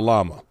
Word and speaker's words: llama: I think double L is a combo, maybe, because you pronounce it like Y. So, like, how you spell llama? L-L-A-M-A. In Spanish, that'd be llama.